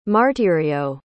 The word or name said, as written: martirio